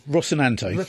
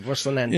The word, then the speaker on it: Rocinante: The Roshane? – Rocinante. – Thank you, Rocinante.